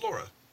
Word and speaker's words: Lara: Does Lara know that you're cutting out letters in her name? – It's Lara. – Lara. – It's Lara. – Lara. – There you go, that's another acceptable pronunciation.